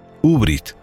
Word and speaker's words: Aubrite: Aubrite es como se escribe en español, pero es una palabra francesa, y Google me dice que debe pronunciarse en francés Ubrit.